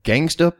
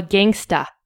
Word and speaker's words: gangsta: You have to pronounce it the way it's spelled. – Gangsta. I didn't see that there was an A at the end of there.